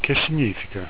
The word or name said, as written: che significa